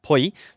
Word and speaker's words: っぽい: So Ppoi is actually, first you have to make sure you spell it right. So the first, it's the chitsaitsu, that's maltsu, comes before the p sound. So it's Ppoi.